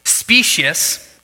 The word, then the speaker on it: specious: I did not know this was a word before this week, but it's specious. I looked up how to pronounce it. That's how you pronounce it.